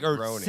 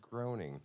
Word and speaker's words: Groening: Groening. Say the name. No, it's Groening. – That was Groening. – No, that's how it's spelled. – Yeah. – But it's pronounced Groening.